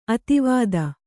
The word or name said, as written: ಅತಿವಾದಿ